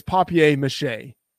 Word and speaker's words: papier-mâché: Yeah, Tom, by the way, if we're using the Seinfeld pronunciation, which obviously is the way that we do it on the show, it's papier-mâché, okay? Papier-mâché.